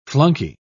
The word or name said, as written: flunky